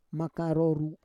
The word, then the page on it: maka roru: Roon